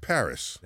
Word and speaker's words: Paris: Just Paris. – That's right. Like the city.